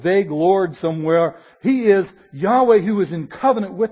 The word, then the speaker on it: Yahweh: It is sometimes transliterated Yahweh into Jehovah, which is really, there are no J's in Hebrew, so that's really a mispronunciation, but Yahweh would be the correct pronunciation.